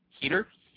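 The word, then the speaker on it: Heder: Moving on, last night, Monday Night Raw, we had Don Johnson and John Heater, right? Heater? – Yes. – Yes. That's how you say it? – Yes.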